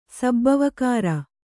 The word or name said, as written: ಸಬ್ಬವಕಾಱ